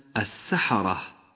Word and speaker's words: السحرة: السحرة في قوله تعالى فلما جاء السحرة لونت اللام بالرمادي لأنها لا تلفظ فهي لام شمسية ولونت الراء